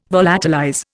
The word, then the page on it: volatilize: Indian